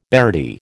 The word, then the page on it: beardie: American English